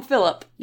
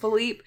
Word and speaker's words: Philippe: They always call him Philip, and I didn't know if that was something of, like, Fran is calling him Philip because she doesn't know that it's pronounced Philippe, but they also never reference that it's supposed to be Philippe.